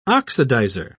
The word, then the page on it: oxidizer: British English